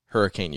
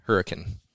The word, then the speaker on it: Hurricane: Hurricane is how you, the locals say it. Ah. It's spelled Hurricane, but if you say it there, they're like, it's Hurricane.